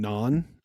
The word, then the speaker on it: naan: I've been making pizzas at home using naan.